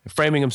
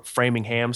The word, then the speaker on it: Framingham: Framingham State, or Framingham State, if you want to overpronounce it,